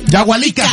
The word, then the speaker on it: Yahualica: Yahualica, Jalisco.